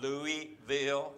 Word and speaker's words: Louisville: It's not Louisville. It's Louisville. and I grew up in Louisville.